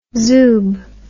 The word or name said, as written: zoob